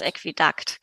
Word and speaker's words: Aquädukt: Und dann gibt es noch in London die Aquädukt. – Haben Sie damit auch Äquidakt. – zu tun? Oder, oder Aquädukt? – Aquädukt, genau.